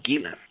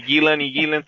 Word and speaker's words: Gillan: Y Gillan también suena muy parecido porque la A no es una A como la nuestra, sino que es más cerrada.